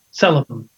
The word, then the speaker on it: selem: Image comes from the Hebrew word selem. Did I get that fairly right? – Oh, yeah. Accent on the first syllable there. Selem. – Okay. – Selem. Yeah.